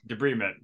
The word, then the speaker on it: debridement: And I found out later they were doing something called a, I don't know if I'm pronouncing this word right, debridement. – Yeah, debridement.